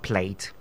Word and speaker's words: plait: So the word is not plait like women when going to dress your hair at the salon is pronounced plait. Plait is not plait. She had a plait yesterday. So plait is pronounced plait, not plate. So take note.